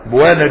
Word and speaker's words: بوانة: بوانة دي تنطق بضم الباء وبعد الألف نون، وقيل بالفتح، هي هضبة من وراء ينبع كما في النهاية لابن الأثير مكان.